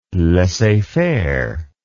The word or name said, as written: laissez faire